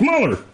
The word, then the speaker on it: Mueller: Or Mueller, or Mulehouse, or whatever the hell her name is. – Yeah, I think it's Mueller. – Mueller? Boy, I thought I butchered it. – No, it's Mueller.